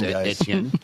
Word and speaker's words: Etienne: By the way, did you notice he nailed the new pronunciation that's not really new, but the old pronunciation that's still now pronounced new? – Travis Etienne. Right. – Because the ETN is out. ETN is not ETN anymore.